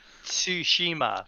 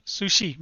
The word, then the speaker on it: Tsushima: I know I keep saying it wrong. I'm sorry. – Tsushima. – Tsushima, yeah. – Tsu. It's like tsunami. – Tsushima.